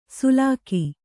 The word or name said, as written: ಸೂಲಗಿ